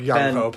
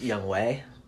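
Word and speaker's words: Young Hoe: It's not actually pronounced Young Ho. – No! Yeah, that totally makes sense. – It's apparently pronounced Young Wei, which I don't quite get spelling-wise, but, you know, Korea.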